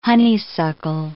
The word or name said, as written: honeysuckle